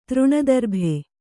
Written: ತೃಣದರ್ಭೆ

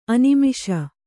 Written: ಅನಿಮಿಷ

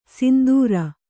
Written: ಸಿಂಧೂರಿ

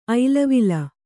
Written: ಐಲವಿಲ

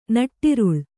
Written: ನಟ್ಟಿರುಳ್

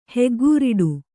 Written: ಹೆಗ್ಗೋರಟೆ

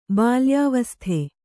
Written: ಬಾಲ್ಯಾವಸ್ಥೆ

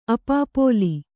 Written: ಅಪಾಪೋಲಿ

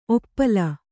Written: ಔಪಲ

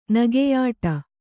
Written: ನಗೆಯಾಡು

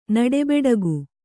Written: ನಡೆಬೆಡಗು

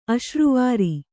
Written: ಅಸುರವೈರಿ

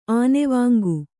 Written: ಆನೆವಾಂಗು

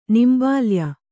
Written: ನಿಂಬಾಲ್ಯ

